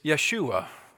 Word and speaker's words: Yeshua: The Hebrew pronunciation is Yeshua, which is the same rendering for Jesus' name in Hebrew.